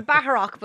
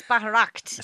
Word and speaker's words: Bataract: I'm saying Batarak, but it's Bataract.